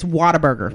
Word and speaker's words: Whataburger: It's Whataburger.